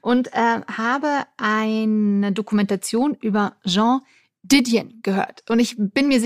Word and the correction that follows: Joan Didion: Äh, du meinst äh, Joan Didion? – Ja, Joan Didion.